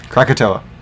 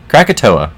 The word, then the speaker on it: Krakatoa: Krakatoa. – Krakatoa.